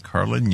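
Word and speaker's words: Karlin: Is that Carlin? Carlin? – I'm going to spell it for you. It's K-A-R-L-I-N. Carlin Younger, who at that time worked for FirstNet, which was tied to law enforcement.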